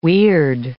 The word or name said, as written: weird